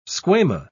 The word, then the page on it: squama: British English